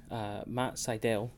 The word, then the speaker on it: Sydal: Next match, Matt Sydal, which is how you pronounce it, by the way. I made a note of that.